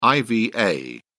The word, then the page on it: iva: British English